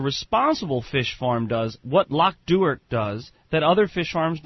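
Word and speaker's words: Loch Duart: Locke Duhart. Think Stewart with a D.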